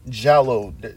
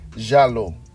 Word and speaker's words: giallo: 3 giallo. I'm not even saying it correctly. Jesus Christ. I'm rusty. Giallo. That's how they pronounce it.